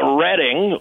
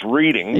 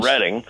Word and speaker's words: Reading: Oh, well, that would be take a ride on the Redding, often mispronounced Reading, but yes. – Redding.